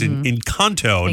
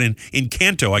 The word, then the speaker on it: Encanto: It's Encanto, not in Encanto.